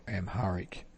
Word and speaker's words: Amharic: And 9485, the Voice of America from Wolverton in England, with its programs in Amharic, I think that is pronounced, Amharic.